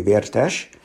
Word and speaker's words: Vertes: Well, I'll say it Hungarian style, and that was Leslie Vertes, or if you really want to get Hungarian, Vertes Laci, who was a remarkable man, 97, I believe.